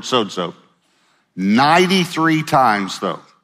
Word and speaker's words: sozo: It's a Greek word. It's pronounced sozo.